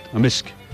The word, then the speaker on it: amisk: In Amisk. – So the K can be silent, but in general it's...